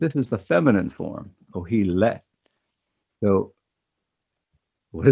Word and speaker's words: Qohelet: But the Hebrew name for the narrator is Kohelet, that's spelled Q-O-H-E-L-E-T, and it's pronounced Kohelet.